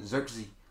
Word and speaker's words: Zirkzee: Zirkzee. That's how you say his name.